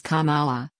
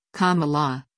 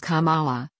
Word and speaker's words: Kamala: Harris' first name is pronounced Kamala, or, as she explains in her biography, Kamala, like the punctuation mark. But mispronunciations have been rampant in the days since the California senator became the first Black woman and the first Asian American woman named to a major party's ticket. Pence referred to her as Kamala, putting his emphasis on the second syllable, at events last week.